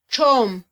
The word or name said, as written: txom